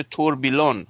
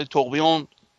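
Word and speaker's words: tourbillon: ره نه ببین تلفظی که میگم فرانسوی، شما میگم نوشتنی میشه توربیلون. – توربیلون توربیلون می نویسن، ولی توقیون تلفظ فرانسه شه.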